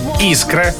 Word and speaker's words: искра: Ну, давайте так, как правильно произносить? Давай. Свекла, искра, грушевый, полшага, оптовый. Именно так и никак иначе.